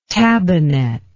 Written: tabbinet

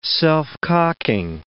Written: self-cocking

